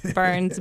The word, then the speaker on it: Byrnes: And my last name is spelled funky, just like my first name, Burns, B-Y-R-N-E-S.